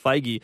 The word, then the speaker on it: Feige: I think I said Feige this whole time. Maybe that may be like the Canadian in me, but I think his name is actually pronounced Feige.